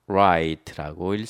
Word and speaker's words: rite: R-I-T-E 읽을 때는 right라고 읽습니다. right.